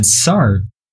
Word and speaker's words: Sartre: Sartre? How do you say it? – Jean-Paul Sartre.